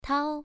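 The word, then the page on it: tao: tone 1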